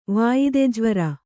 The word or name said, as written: ವಾಯದೆಜ್ವರ